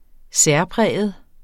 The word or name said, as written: særpræget